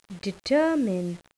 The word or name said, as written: determine